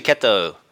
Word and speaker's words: Kettou: Is it Kettou or Kettou? – It's actually Kettou. It's kind of a weird pronunciation there at the end with the Japanese, but Kettou is fine.